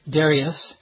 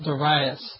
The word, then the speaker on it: Darius: and when we're American or English, we tend to put the pronunciation on the first of three syllables, Darius, whereas I think the more accurate way to pronounce it in the Greek would put the emphasis on the second syllable, Darius.